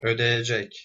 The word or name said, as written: odeyecek